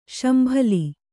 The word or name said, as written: ಶಂಬಲ